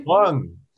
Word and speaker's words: McClung: McClung? Is that how you pronounce his last name? – McClung.